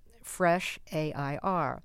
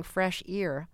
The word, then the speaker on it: Fresh Air: Okay, and it's the name of our show, which is Fresh A-I-R. And yeah. I always fear that I'm saying fresh ear, as in like fresh E-A-R. – No. It doesn't sound that way to me at all.